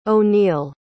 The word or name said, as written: oneal